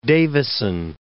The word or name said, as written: Davisson